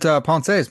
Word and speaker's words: Pensées: So you probably even pronounced it Pensées, man.